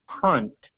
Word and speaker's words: Hunt: Bradley Hunt.